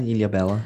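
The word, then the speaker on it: Ilhabela: That's fine, yeah, Ilha Bela.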